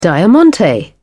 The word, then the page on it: diamante: British English